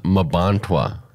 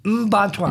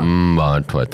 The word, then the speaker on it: Mbantwa: Hey, it's not pronounced Mabantwa. – Mbantwa. – Mbantwa, thank you.